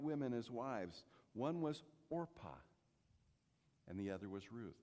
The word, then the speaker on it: Orpah: Orpah, just a little cultural side note, Oprah of today was supposed to be named Orpah, and they mispronounced it, so she stuck with the name Oprah.